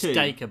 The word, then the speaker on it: Dacre: And he's visited by a wheelchair-bound man called John, played by, I'm going to butcher the name again, I think it's Dacre Montgomery, the Red Power Ranger. – Yes. – Who's also in Strange Things 2. – It's Dacre, by the way.